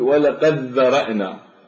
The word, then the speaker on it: ولقد ذرأنا: تقول ولقد ذرأنا، فهذا الدال لا يبقى له صوت ويشدد حرف الذال ويدغم الدال بالذال.